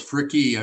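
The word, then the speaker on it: Fricke: Real close, Fricky. I pronounce the E on the end.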